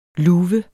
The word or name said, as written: luve